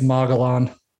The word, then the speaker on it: Mogollon: is it Mongol? Is that how you say it? Mongolian? – I think it's Mogollon.